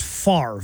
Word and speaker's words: Favre: In fairness, though, his name is pronounced Favre. – Fair. – And the R is after the V.